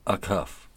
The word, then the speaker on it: Acuff: You gotta put that you gotta pronounce that A. – Acuff. – It's not a cuff. It's a cuff.